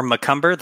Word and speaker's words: McCumber: Like you said before, it's Parker underscore McCumber.